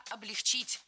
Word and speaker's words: облегчить: Не облегчить, а облегчить. Не облегчили, а облегчили. Не облегчит, а облегчит. В глаголе «облегчить» и во всех его формах ударение ставим всегда за корнем, а корень оставляем безударным.